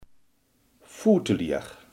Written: valsspeler

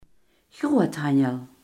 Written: groothandel

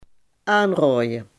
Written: aanraden

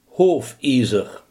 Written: hoefijzer

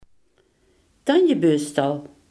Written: tandenborstel

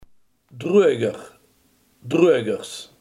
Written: wasdroger

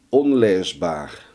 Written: onleesbaar

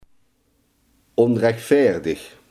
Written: onrechtvaardig

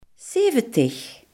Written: zeventig

